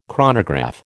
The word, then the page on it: chronograph: American English